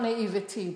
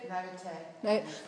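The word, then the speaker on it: naiveté: not naivety. Did I say it right? Naivete? Mm-hmm. Which one? Naivety, naivete? – Naivete.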